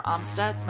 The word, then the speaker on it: Amstutz: follow me on every social media platform at Sandra Amstutz.